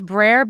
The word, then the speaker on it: Breyer: And it's Breyer because it looks like Breyer's ice cream.